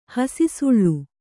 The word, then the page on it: ಹಸಿಸುಳ್ಳು: kannada